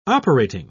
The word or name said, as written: operating